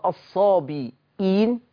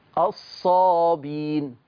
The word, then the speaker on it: الصابئين: كلمة الصابئين دي بحفص، صح؟ مش ملاحظين ان هو نطق الهمزة الصابئين؟ طب ورش بيقول ايه؟ شوفوا كده ورش، الصابين.